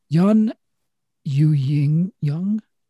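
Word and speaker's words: Youn Yuh-jung: Yun Yu Jung.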